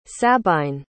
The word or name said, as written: sabine